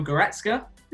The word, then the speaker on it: Goretzka: Now, option number one was Leon, I'm going to say his name wrong again, Leon Goretzka.